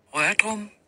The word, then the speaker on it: rørdrum: Nå, det var fordi Frederik efterspurgte, hvordan man udtalte det. Rørdrum. Så der har I det.